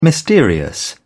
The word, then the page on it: mysterious: British English